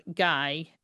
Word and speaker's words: Gail: An observant young detective named Gail Leonard, and even though it's spelled G-A-I-L, it's Guy, just FYI, noticed fresh cement around the septic tank.